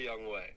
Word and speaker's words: Younghoe Koo: Young Wei. – Okay, I say Younghoe. So agree to disagree. – Yeah, it's definitely Young Wei.